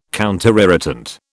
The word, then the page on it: counterirritant: British English